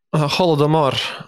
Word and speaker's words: Holodomor: The chapter that I'm reading right now is on, I'll pronounce it wrong, but the Holodomor? How do you pronounce it? – Holodomor.